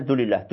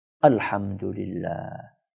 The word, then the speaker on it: Alhamdulillah: Karena pengen cepat selesai, maka dia bacanya Dulillah, Dulillah, Dulillah. Apa itu Dulillah? Ya. Jadi membaca zikir, memuji Allah subhanahu wa ta'ala dengan penuh penghayatan yang baik, tayyiban. Ya. Alhamdulillah.